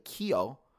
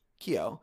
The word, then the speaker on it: Keough: Riley Keough. – Keough.